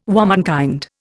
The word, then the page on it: womenkind: Indian